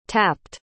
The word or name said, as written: tapped